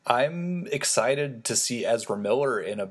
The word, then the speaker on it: Ezra Miller: They've got these huge plans, and they also let slip in that Ezra Miller, is that how you pronounce his name? – Yep.